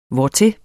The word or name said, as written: hvortil